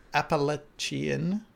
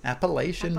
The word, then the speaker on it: Appalachian: I'm going to butcher this, the Appalachian Mountains. – Appalachian Mountains. Nailed it.